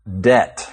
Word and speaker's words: debt: His English was great, except for one word he mispronounced again and again, debt. – Which is spelled D-E-B-T, and the B should be silent.